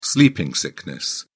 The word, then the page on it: sleeping sickness: British English